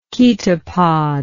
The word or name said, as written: chaetopod